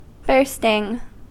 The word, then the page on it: bursting: American English